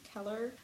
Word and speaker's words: Keller: So one of the, I think it was one of generally designers and ideologists behind Bauhaus, Ernst Keller, that's again a better German pronunciation, Keller.